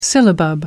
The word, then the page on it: syllabub: American English